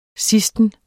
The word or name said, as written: sidsten